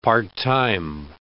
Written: part-time

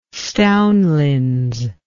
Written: stownlins

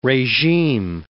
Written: regime

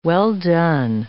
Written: well-done

